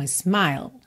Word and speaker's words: smile: Repare que a letrinha E está pintada de cinza. Então, isso significa o quê? Que você não vai pronunciar, tá? Nem um pouquinho. Então, é smile, smile.